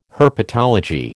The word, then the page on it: herpetology: American English